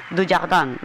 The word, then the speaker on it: Dujardin: Aunque James Joyce decía que él lo había descubierto en un libro de, bueno, yo no sé francés, me corriges la pronunciación, Édouard Dujardin. – Sí. – ¿Ya habían dicho? – Dujardin, vale.